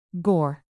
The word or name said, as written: gore